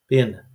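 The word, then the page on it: pena: Portuguese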